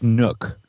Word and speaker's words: Nuuk: And I believe it's pronounced Nook. – Which is the city that the Dash 8 led the Cessna to. – Okay. Wait. If it's pronounced Nook, it must be spelled really unusually. What's this? – One more try. Nuuk. – Okay, so it's just Nook.